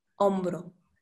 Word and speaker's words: hombro: For example, hombre, hombro. Hombre means man, hombro, shoulder. Or the pronunciation, yes, it's tricky because the vowels is a clue.